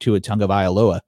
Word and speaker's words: Tagovailoa: The other two quarterbacks on my thumbnail tonight with Justin Herbert and Tua Tagovailoa.